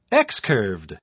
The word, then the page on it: excurved: British English